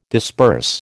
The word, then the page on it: disperse: American English